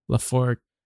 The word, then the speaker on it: Lafourcade: Lafor- Lac- Lacaforda. – There's no C in it though. L A F O U R C A D E. Laforcade.